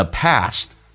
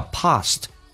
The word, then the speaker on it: past: 下面一个呼出，exhale the past，打开自己的口腔，英式发音叫 past。美式发音叫 past，发 a 这个发音。